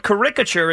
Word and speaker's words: caricature: The word caricature is used in music and literature as well as in the visual arts. The correct pronunciation is definitely with the accent on the first syllable, not the second.